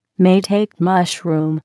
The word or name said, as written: maitake mushroom